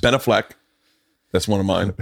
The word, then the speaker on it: Ben Affleck: My heart will melt if I get a text from Ben Affleck and says, hey, man, Ben Affleck.